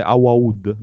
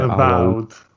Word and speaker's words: Avowed: dice Awawud, mi scrivono qua, si dice Awawud.